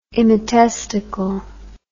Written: immatesticle

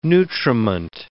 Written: nutriment

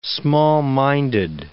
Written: small-minded

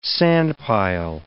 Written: sandpile

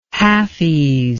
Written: halfies

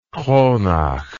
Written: Kronach

